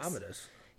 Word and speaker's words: Commodus: It's Commodus.